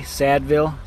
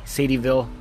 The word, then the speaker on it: Sadieville: Sadville? Sadville? Sadie? Sad, probably spelled, pronounced like Sadie, Sadie, Sadieville, Kentucky. I don't know, but I want to call it Sadville, Kentucky.